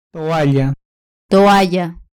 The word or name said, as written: toalla